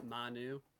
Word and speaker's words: Manu: um, the Oklahoma City Lodge was Manu, M A N U. Mm-hmm. And people kept saying Manu, Manu, Manu, Mona, whatever.